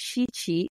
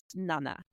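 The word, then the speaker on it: shichi (七): I don't think I've ever seen the fourth floor be excluded, but they've definitely used the other word for 4 because there's shi, which is 4, and then there's 7, which is shichi. But 4 can also be pronounced as yon, and 7 can be pronounced as nana.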